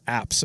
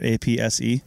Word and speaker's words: apse: And am I pronouncing it right? APSE? – Yeah, apse. – Apse. Yeah. – I thought I put the definition. – Not to be mistaken for an asp.